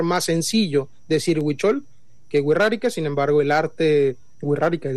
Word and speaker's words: Wixárika: Así es, básicamente en la cultura la X muchas veces es utilizada como R, o bueno, pronunciada como doble R incluso. – Wixárika.